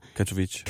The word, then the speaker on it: Katowice: Katowice. K, altså Kato som i DJ'en, ham den skaldede, og så Vice som i W-I-C-E